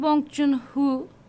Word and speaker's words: Bong Joon-ho: E, yönetmeni Bong Joon-ho diye telaffuz Bong ediliyordu.